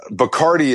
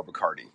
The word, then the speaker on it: Bacardi: But Bacardi is the most popular like store brand, correct? – Yeah, it is. It's the Budweiser of rum, and also it rhymes with party, so it really has been the subject of a lot of lazy lyricism over the years.